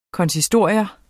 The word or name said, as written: konsistorium